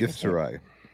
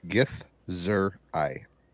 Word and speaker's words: Githzerai: How is it pronounced? I always feel like... – Githzerai. – What is it? – Githzerai. – Okay. – That's how I say it.